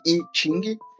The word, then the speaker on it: I Ching: É, ele se escreve I Ching, mas a pronúncia é I Ching.